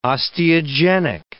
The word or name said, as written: osteogenic